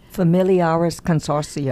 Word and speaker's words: Familiaris Consortio: And I don't think I had heard that much about this from Familiaro. Say it right? Familiaris Consortio.